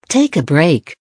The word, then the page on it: take a break: American English